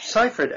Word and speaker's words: Seyfried: Yeah, Seyfried.